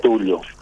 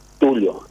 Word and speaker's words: Tulio: Algunos lo leen como lo decís, Tulo. Sí. Pero, no sé, la mayoría pronuncian como se lee, Tulio. – ¿Pero cómo vos decís? – Tulio.